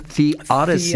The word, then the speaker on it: theodicy: Theodicy.